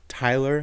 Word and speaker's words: Tyler: No, she would go, Taylor Rowe. No, Tyler.